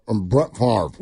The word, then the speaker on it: Favre: Brett Favre.